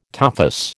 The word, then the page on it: tophus: American English